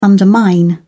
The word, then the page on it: undermine: British English